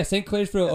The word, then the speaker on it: St. Clairsville: Did I get that right? Yeah, St. Clairsville, Ohio.